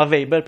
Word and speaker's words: Weber: Questo si chiama Weber, perché è tedesco, quindi cerchiamo di ricordarcelo, come la Volkswagen, non si dice Wagen, ecco.